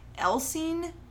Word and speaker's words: Elsene: okay, so Marc Dutroux was born in uh, Elsene, Belgium on November 6th, 1956 to Victor and Janine Dutroux Now, Elsene, E L S E N E, is the Dutch pronunciation. The actual name of the place he was born is capital I X E L L E S.